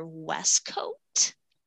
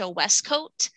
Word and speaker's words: Wescoat: And if you go to YouTube, I had an old name, so they'd have to find me under Westcoat. I'm going to spell it. It's okay. WESCOAT, Natasha Westcoat.